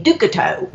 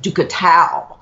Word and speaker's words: Duchateau: spelled D-U-C-H-A-T-E-A-U. In Louisiana, that would be Ducato. But I think that she told me that it's pronounced Ducatow.